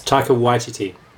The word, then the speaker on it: Taika Waititi: Taika Waititi's Thor Ragnarok. – The emphasis is on the why, just for future reference. – What? – It's Taika Waititi.